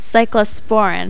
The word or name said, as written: cyclosporine